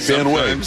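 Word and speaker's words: Van Wey: Van Wy. Logan, yep. Van Wy. 2-2 pitch is fouled back. It is spelled Van Wy, but it is Van Wy.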